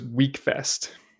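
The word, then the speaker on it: Wekfest: It's spelled W-E-K and pronounced week. – Okay. I would definitely call that Weckfest, but okay.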